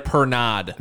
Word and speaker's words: Pernod: Pernod. – That's how you say it.